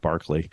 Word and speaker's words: Berkeley: But actually, I love going around here in Berkeley and explaining to people that Berkeley in England is pronounced Barkley. But it's Bishop Barkley, but it's Berkeley, California.